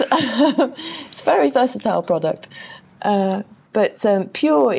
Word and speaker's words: Pjur: There's a really good one that I like called Pure. It's spelled P-J-U-R.